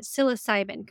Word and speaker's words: psilocybin: you called it psilocybin, correct? – Yes. – Yes. Okay. I said it right.